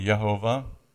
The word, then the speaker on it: Jehovah: By the way, when I say Jah, somebody came and corrected me some years ago and said, isn't it Yah? Yeah, it is. But it's J here in your Bible, so I'm going to use American pronunciation for now. But Jehovah is really Yehovah, and Jemuel is probably Yemuel, and Yamin, and Ohad, and Yakin, and so on.